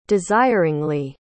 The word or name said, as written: desiringly